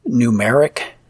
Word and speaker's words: Gnumeric: I use Numeric for spreadsheets, and again, that's spelled G-N-U-M-E-R-I-C, that G-N-U moniker.